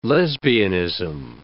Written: lesbianism

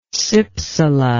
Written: cypsela

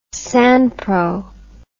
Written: sanpro

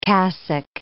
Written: cassock

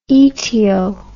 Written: etio

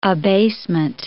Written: abasement

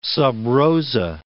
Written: sub rosa

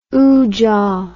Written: oojah

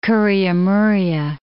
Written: Kuria Muria Islands

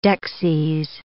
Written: dexies

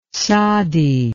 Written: tsadi

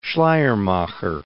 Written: Schleiermacher

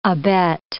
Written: abet

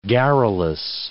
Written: garrulous

